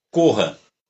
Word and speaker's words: Koha: Corra, K-O-H-A. É assim que se escreve esse, ah, o, o, o nome da Corra, dessa, desse site de inscrição. Isso. K-O-H-A. Corra. Lá tem a corrida.